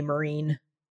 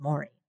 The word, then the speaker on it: Maureen: That there are a number of members of my family that can't pronounce my name and would always call me Marine. – Marine. – And I say it's, I wouldn't say it, but in my head I'd be like, it's Maureen. Like it's just, it's like more.